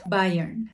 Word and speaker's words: Bayern: ¿Cómo se pronuncia eso? Bayern. Bayern Munich. Múnich.